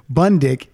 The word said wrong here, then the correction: Bundrick: Oh, I'm sorry. I'm saying it wrong. I'm saying his name wrong. It's John Rabbit. Bundrick. It's not Bundick.